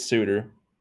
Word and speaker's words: Suter: It's Suter, and I'm gonna be mad if they don't give it to him.